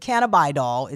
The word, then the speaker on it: cannabidiol: And depends on what part of the country you're from, you may pronounce it differently. Right. But cannabidiol is how it's pronounced.